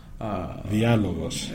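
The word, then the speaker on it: Dialogos (Διάλογος): And it has the same meaning, and actually you pronounce it the same way that we pronounce it in Greece and in Cyprus. – Dialogos.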